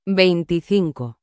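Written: veinticinco

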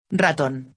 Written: ratón

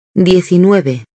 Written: 19

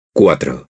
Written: cuatro

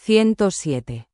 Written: 107

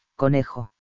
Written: conejo

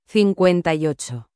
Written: 58

